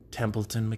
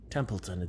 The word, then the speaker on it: Templeton: It's your host, Mike Templeton McCowan. Thinking about just leaving off the McCowan. There's a lot of C's, a lot of confusing letters in it. McCowan. That's three like like syllables, you know. Templeton.